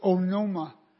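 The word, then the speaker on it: Onoma (ὄνομα): The name, the Greek word is onoma. I think I'm pronouncing it right. We get the word anonymous. Some people have the A, but it's actually an O.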